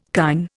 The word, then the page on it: kine: Indian